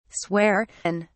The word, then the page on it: swear in: British English